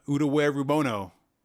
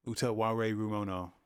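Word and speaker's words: Utawarerumono: Utaware Rumono. – What? – I said that wrong. Utaware Rumono. Utaware Rumono.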